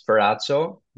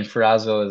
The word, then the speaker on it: Ferrazzo: Well, if you want the proper Italian pronunciation, it's Ferrazzo. But okay. – I mean, Ferrazzo is what people would, I guess, you know, come to pronounce it as.